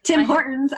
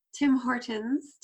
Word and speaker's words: Tim Hortons: Tim Hortons. I love the way you say it. That's not how Canadians say it, but that's okay. – How do you say it as a Canadian? – Tim Hortons.